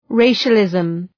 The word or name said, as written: racialism